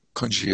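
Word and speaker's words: kun gzhi: You see in the Tibetan there, kun, K-U-N, and then G-Z-H-I-I, which is pronounced G. And then the word R-N-A-M, and don't pronounce the R, you say Nam She. So you have the She again from Shepa. So Nam She, as I said earlier, is Vijnana, and mm-hmm. Kunji is Alaya.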